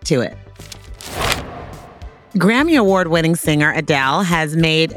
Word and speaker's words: Adele: He came and asked me how I say my name, and I was like, Adele.